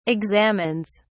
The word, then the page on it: examines: British English